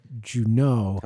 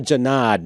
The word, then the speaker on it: Junod: Yes, so he changed, the son changed the pronunciation of his name in college. He was taking a French class, and the teacher started pronouncing his name Juneau, and he said, I like it, I'm going to change it.